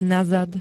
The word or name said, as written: nazad